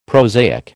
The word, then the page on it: prosaic: American English